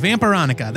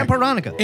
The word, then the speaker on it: Vampironica: It's like Vampirella, but with Annika at the end. Vampironica. There. – Vampironica.